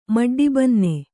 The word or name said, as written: ಮಡ್ಡಿಬನ್ನೆ